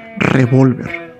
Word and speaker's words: revólver: Y ojo con esto, hay que poner atención a la hora de leer esta palabra, ya que si no lleva tilde es revolver, que no es lo mismo que revolver.